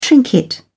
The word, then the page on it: trinket: British English